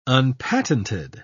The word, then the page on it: unpatented: British English